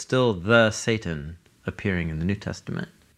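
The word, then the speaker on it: Satan (שטן): It's the Hebrew word, Satan.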